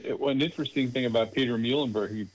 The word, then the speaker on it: Muhlenberg: Was it Peter Muhlenberg? Am I saying that correctly? – Muhlenberg.